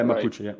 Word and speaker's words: Mapuche: Or Mapuche, is Mapuche the right way to say it? – Yeah, Mapuche, right.